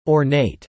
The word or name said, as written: ornate